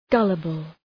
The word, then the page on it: gullible: ‘gʌləbəl